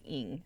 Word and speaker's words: Ng: Yeah, but like I read Celeste Ng's, how do you pronounce her name? Little Fires Everywhere. – Yeah, I think it's like Ng.